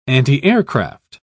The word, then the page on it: antiaircraft: British English